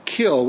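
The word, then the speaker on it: kiln: If she pronounced the N, he stopped her. And he said, you do not pronounce the N. It is kill,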